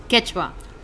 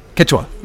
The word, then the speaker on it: Quechua: Did I say Quechua? Say it again. – Quechua. – Quechua. Is that okay? – Yes.